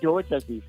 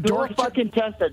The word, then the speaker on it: Dorchester: From DeChester, Massachusetts, right? – You're fucking saying it wrong, dude. It's fucking Dorchester. – Dorchester? Is it dark? – Dorchester, dude.